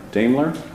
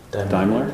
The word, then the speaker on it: Daimler: I want to read you a press release from the German car and truck maker Daimler? How do you say it? – Daimler? – So this is from a press release.